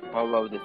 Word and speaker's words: バウワウ: あのバウアウのイントネーションなんですけど、バウアウですね。